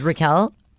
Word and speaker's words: Raquel: When people, you know, my first name is Raquel. Even though everyone calls me Roxy, but people are like, Rachel, when you're at the doctor, Rachel.